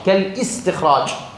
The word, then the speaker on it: كالاستخراج: ومصادر الأفعال السداسية كالاستخراج، لا نقول كالاستخراج، كالاستخراج لا، الهمزة همزة وصل فتقول كالاستخراج.